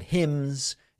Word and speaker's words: hymns: It was 3+ hours of stories and prayers and hymns. Is that how you pronounce it? Hymns? The N is silent.